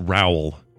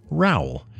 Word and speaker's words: Raoul: The Luxembourg follow-up from Raoul, which I now know is pronounced Raoul, like R-O-W-L. Raoul. – Okay. All right. Good. – He says, hi. I don't remember how we pronounced it before. – I think I said Raoul because I didn't know the way it was spelled. – Yeah. – But he says, hi. Love the Luxembourg part of the last show. You guys asked about pronunciation of my name. It was nearly perfect, but it's actually pronounced as you would say growl.